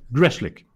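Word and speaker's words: Grzelcyk: Jag tror att det ska uttalas Greshlik. Ja, det ska det. Eh, ja, han har de gett ett nytt kontrakt då på 3,6 miljoner dollar i fyra år eller vad det var.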